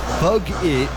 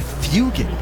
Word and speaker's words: Fuggitt: Then the middle name, spelled F-U-G-G-I-T-T, which a lot of people pronounce as it looks, as bug it, but it's actually Fugit.